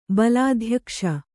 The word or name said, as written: ಬಲಾಧ್ಯಕ್ಷ